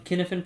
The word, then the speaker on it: Cynefin: So, you know, we're here to talk about the Kinefin Project, and that's spelled C-Y-N-E-F-I-N.